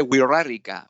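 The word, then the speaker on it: Wixárika: Well, in this film, you spend time with, I don't know how to pronounce it, the Wixarica people. How did you come in contact with them? – Yeah, it's called the Wirarica people.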